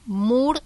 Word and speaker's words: Moore: Bueno, pueden comunicarse al Instagram o a la página web Moore y Asociados, que se escribe MWRE y Asociados, como Demi Moore, de la misma forma.